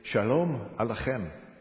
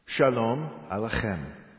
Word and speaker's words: Shalom Aleichem: Shalom Aleichem. Okay, now it has the letter chet, and you pronounce it the chem. Starts way back in the throat.